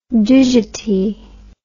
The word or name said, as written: digiti